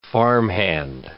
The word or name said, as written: farmhand